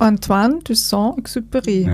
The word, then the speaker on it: Antoine de Saint-Exupéry: Antoine de Saint-Exupéry, ja, cool, würde ich sagen.